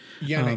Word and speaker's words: Janick: Yannick um, Gers.